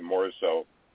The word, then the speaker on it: Morisot: And by the way, Morisot, I agree, is correct.